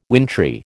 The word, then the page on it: wintry: American English